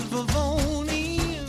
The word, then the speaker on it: Vivone: My name is V-I-V-O-N-E. It's pronounced Vivoni. It's not Vivonne and it's not Vivonne. My name don't rhyme with Al Capone. V-I-V-O-N-E.